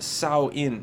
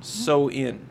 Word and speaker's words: Samhain: So you've got this festival celebrating Samhain, pronounced Sow-in. Mm-hmm. So-in.